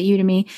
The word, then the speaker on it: Udemy: He's even on Udemy. I think that's how you pronounce it. Udemy.